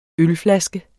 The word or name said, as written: ølflaske